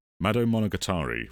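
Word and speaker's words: Madou Monogatari: And so most of the core cast comes from, forgive my pronunciation, but it's Mado Monogatari, which is a... – That's actually pretty good.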